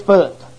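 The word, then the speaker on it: Phut: P-U-T. But here, the H is silent. I mean, like Philadelphia. Now, the P and the H turns into an F. And his name is Foot.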